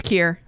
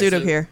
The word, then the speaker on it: Kier: Udo Kier, is it Udo Kier? – It's Kier. It's Udo Kier.